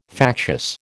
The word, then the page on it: factious: American English